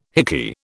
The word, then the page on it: hickey: British English